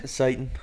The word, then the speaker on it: Seton: Satan. – Satan Hall. – Why you call him Satan? – Yeah. – Oh, that's how you say it. – S E T O N.